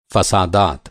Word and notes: फ़सादात: فسادات; urdu

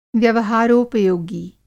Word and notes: व्यवहारोपयोगी: Hindi

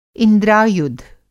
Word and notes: इंद्रायुध: Hindi

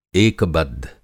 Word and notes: एकबद्ध: Hindi

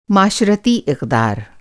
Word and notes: इक़दार: اقدار

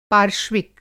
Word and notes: पार्श्विक: Hindi

